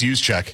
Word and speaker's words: Juszczyk: Let's say, how's it pronounced? Juszczyk? – I think it's Juszczyk. – I think you're right.